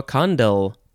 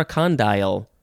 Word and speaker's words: condyle: C-O-N-D-Y-L-A-R. Condylar. Adjective from 1876 of or relating to a condyle or a condyle. What is that, you may be asking? It is spelled C-O-N-D-Y-L-E. It is a noun from 1634. And yeah, you can pronounce it condyle or condyle. Yeah, condyle.